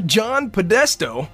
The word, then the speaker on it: John Podesta: New Obama advisor John Podesta.